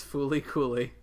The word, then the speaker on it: Fooly Cooly: FLCL, pronounced as Fooly Cooly, is an anime series written by Yoji Enokido, directed by Kazuya Tsurumaki.